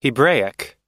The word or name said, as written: hebraic